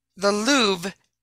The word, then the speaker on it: Louvre: Which glass shape would you see outside the Louvre in Paris? I think that's how you say it. L-O-U-V-R-E.